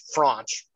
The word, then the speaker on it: Franch: So for my first female pick, I am going with Adriana French, goalkeeper, women's soccer team. – Okay. – Sorry, not French, Franch.